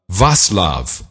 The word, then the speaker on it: Václav: you spell the prime minister's name V-A-C-L-A-V, but you do not pronounce it Vaclav. You pronounce it Václav Klaus. – Is it Václav? – It's Václav. The okay. C apparently is pronounced as an S.